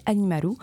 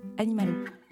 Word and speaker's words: Animaru: Après avoir réalisé plusieurs EP, la chanteuse a décidé cette année de sortir son tout premier album intitulé Animaru, en référence à la prononciation japonaise du mot animal.